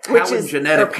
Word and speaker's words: palingenetic: But she was, like I said, very much about paleogenic ultranationalism. – Right. – Palingenetic means...